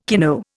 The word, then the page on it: kino: Indian